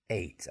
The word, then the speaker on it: ate: Hear the sound? Ate. Almost like the letter or the number 8, although that is spelled E-I-G-H-T, but the sound is the same, 8. I ate my breakfast. I ate my breakfast at 8 o'clock. Two different words, same sound.